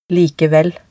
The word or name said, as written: likevel